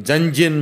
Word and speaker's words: Jungian: And how do you pronounce, how do you spell that again? – J-U-N-G-I-A-N, Junjun model.